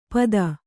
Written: ಪದ

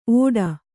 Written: ಓಡ

